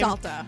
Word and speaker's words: Salta: Romeo Salter, is that how you say his name? – Salta. It's S-A-L-T-A.